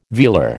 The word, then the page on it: velar: American English